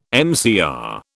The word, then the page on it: MCR: British English